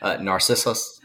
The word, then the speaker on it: Narcissus: Narcissus. – Okay, cool. I was saying it wrong. I was emphasizing the wrong thing.